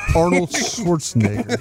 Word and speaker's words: Schwarzenegger: Arnold Schwarzenegger. Did I say it right? – You did.